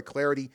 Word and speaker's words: Clardy: Don't forget you can give me the follow on Twitter at Troy Clarity, at Troy Clarity. The last name is spelled C-L-A-R-D-Y.